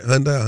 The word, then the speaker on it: Hyundai: Because I'm looking at it right here and it's saying that Kia and Hyundai, Hyundai, am I saying it right? Hyundai Hyundai? Mm-hmm.